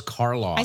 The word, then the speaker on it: Karloff: Boris Is it Karloff or Carlyle? – Oh, my God. Boris Karloff. – I said it right the first time.